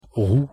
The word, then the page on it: roue: French